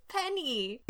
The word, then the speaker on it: Peni: Peni. – No, it's Penny. – It's spelled Peni. It's one letter from you know what.